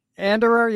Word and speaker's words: Anderer: Anderer, yeah, it's German.